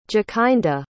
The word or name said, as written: jakinda